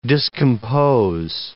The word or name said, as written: discompose